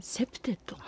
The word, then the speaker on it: CPTED: セプテッド、はい、はい、セプテッドというのは CPTED を、 E がないんですけど CP なんですが、セプテッドと読みまして、